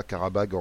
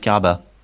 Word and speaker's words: Karabakh: Je suis sûr que ça se prononce pas Karabag en azerbaïdjanais, mais bon. – Non, mais déjà en français, ça se prononce Karabakh.